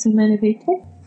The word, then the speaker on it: Humanae Vitae: To that end, I think it's Humanae Vitae. Am I pronouncing that correctly? – Yes, Humanae Vitae.